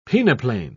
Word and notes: peneplane: British English

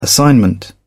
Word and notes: assignment: British English

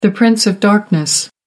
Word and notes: prince of darkness: American English

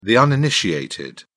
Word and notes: uninitiated: British English

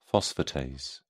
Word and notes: phosphatase: British English

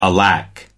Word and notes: alack: American English

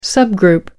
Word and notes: subgroup: British English

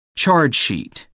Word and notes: charge-sheet: American English

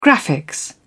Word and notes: graphics: British English

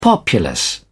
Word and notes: populace: British English

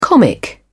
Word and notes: comic: British English

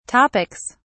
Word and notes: topics: British English

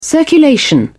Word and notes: circulation: British English

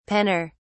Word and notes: penner: British English

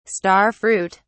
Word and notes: star fruit: British English

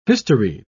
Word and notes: historied: British English

